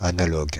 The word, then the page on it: analogue: [a.na.lɔɡ]; Paris